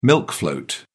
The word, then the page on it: milk float: British English